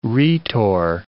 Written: rhetor

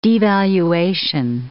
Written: devaluation

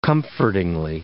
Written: comfortingly

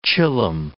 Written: chillum

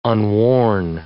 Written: unworn

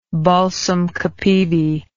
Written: balsam capivi

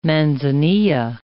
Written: Manzanilla

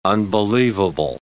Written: unbelievable

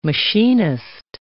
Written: machinist

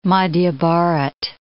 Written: Madhya Bharat

